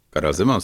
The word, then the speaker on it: Karel Zeman: Alors désolé pour la prononciation, il s'appelle Karel Zeman. – Karel Zeman, c'était bien.